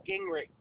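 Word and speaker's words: Gingerich: Douglas, last name Gingrich.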